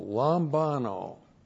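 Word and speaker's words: lambano (λαμβάνω): And then the verb lambano. Looks like that in the Greek, L-A-M-B-A. And long O, lambano.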